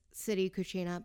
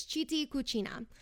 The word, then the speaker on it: Citi Cucina: It's pronounced in English as Brando's City Cucina, but if you're saying in Italian, it's Citi Cucina.